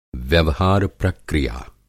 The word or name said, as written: व्यवहार-प्रक्रिया